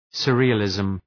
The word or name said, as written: surrealism